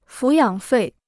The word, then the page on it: 抚养费: fǔ yǎng fèi